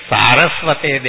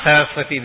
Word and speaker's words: Saraswate: When we say Namaste Saraswate Deve, Saraswate, not Saraswati Deve.